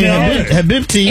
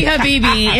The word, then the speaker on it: Habibi: I'm going to say Habibti. – It's definitely Habibi. It's just the way it's spelled. It's Habibi.